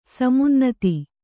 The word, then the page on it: ಸಮುನ್ನತ: samunnata